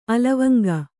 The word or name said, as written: ಅಲವಂಗ